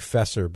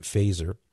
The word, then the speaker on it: Feser: His last name is spelled F-E-S-E-R. It looks like Fesser, but he pronounces it Faeser.